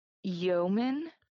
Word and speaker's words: yeoman: So she became I don't know how to pronounce this word, a yeoman. – Yeah, Y-E-O-M-A-N. – Yes. – I think that is pronounced.